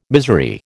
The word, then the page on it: misery: American English